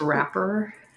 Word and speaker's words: rapper: You're the raper, because his pictures were in all the papers. – I believe, which is another thing. – I believe it's pronounced rapper.